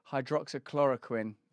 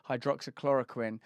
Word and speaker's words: hydroxychloroquine: Do you know how to say it? Hydroxychloroquine. Yeah, that's it. You've got to get that quin. Hydroxychloroquine.